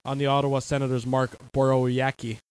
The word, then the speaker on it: Borowiecki: I can't pronounce his name. – Borowiecki. – Borowiecki. – Yep. – That's a tough one for me to pronounce. Your thoughts on the hit? – The W is a V, essentially.